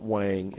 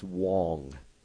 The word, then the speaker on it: Huang: It's not Wang. It's Huang. It's Jensen Huang.